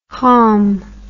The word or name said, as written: hom